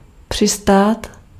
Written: atterrir

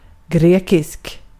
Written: grekisk

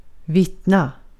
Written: vittna

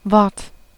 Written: speaking

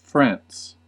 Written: France

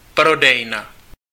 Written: magasin

